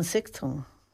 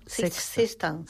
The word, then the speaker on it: Sexton: que es la Anne Sexton. – Ah, sí. ¿Cómo se dice? Anne Sexton. Sexton. Como Sexton de seis.